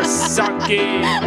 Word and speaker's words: Socci: Oh, yeah, Blair Socci. S-O-C-C-I. – That's how you say your name, is it? – Yeah.